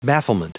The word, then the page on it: bafflement: anglais